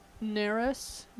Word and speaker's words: Neras: Neras Music sent us this. N-E-R-A-S Music. Neras. – Neras. – I can't pronounce Narissa? – Things.